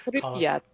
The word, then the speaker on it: Pripyat: But I mean, I think Call of Duty has a Pripyat, I don't know how to pronounce it, whatever. – Pripyat.